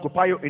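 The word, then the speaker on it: kopayo (κοπιάω): And so, in the labor he was talking about is the same word defined kopayo, or it is pronounced kopayo in the Greek.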